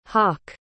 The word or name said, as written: hoc